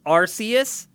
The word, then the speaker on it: Arceus: um, Pokemon Legends Arceus. – Arceus. – Arceus. AKA. – Yeah, that's a, that's a, hard C, believe it or not.